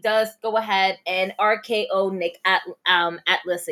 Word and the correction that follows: Aldis: again atlas Aldis. Aldis. Nick Aldis. That, I knew I was saying it wrong yeah, for a second.